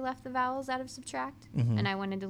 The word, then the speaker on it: SBTRKT: And it's by Subtract, but it's spelled S-B-T-R-K-T. – Just without the vowels.